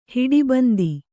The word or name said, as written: ಹಿಡಿಬಂದಿ